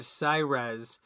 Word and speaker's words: Cyrez: The company, they changed it to Cyrez. – Why? – They originally had it spelled Cyrex and pronounced that way.